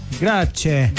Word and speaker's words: grazie: Grazie.